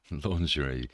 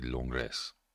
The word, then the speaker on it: Langres: Langres is my pronunciation of a word spelled Langres. I'm sure it's actually Langres.